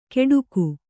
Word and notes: ಕೆಡುಕು: keḍuku

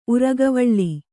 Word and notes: ಉರಗವಳ್ಳಿ: Kannada

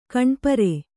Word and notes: ಕಾಣಿಪೇರು: Kannada

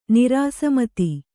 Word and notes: ನಿರಾಸಮತಿ: Kannada

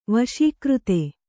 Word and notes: ವಶೀಕೃತ: kannada

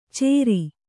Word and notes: ಚೇರಿ: cēri

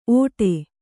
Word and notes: ಓಡೆ: kannada

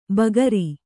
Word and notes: ಬಗರಿ: bagari

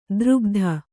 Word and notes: ದ್ರುಗ್ಧ: drugdha

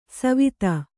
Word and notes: ಸವಿಧೆ: savidhe

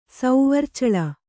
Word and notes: ಸೌವರ್ಚಳ: Kannada

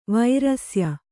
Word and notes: ವೃಷ್ಯ: vřṣya

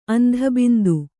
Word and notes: ಅಂಧಬಿಂದು: andhabindu

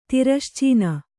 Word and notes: ತಿರಶ್ಚೀನ: tirascīna

